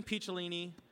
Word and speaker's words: Picciolini: And then Christian Picciolini, long-time general. He pronounced it correctly.